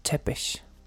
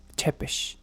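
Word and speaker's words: Țepeș: Tepes, eh, leas en perfecto español, Tepes en algo parecido al rumano, aunque la pronunciación original sería casi como si tuviera eh, dos SHs o CHs como Chepesh. Chepesh.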